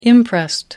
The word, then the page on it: imprest: American English